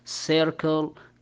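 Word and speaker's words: circle: قلنا حرف السي ينطق حرف السي كما هو اذا اتى بعدها احدى الاحرف الثلاثة اي اي او واي، مثل ضربنا مثال عليها سيركل، قلنا السا نطقناها حرف السا لان اتى بعدها حرف اي، وحرف السا السي الاخر اللي هو الحرف الرابع من كلمة سيركل ك نطقناه بحرف الكاف، السبب لان لم يأتي بعده احدى الاحرف الثلاثة سواء اي او اي او واي.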